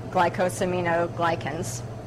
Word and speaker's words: glycosaminoglycans: So bone broth is loaded with the, I always laugh because I'm going to say this word wrong, but glycosaminoglycans.